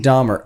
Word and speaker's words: Dahmer: Last name Dahmer. It's pronounced Dahmer.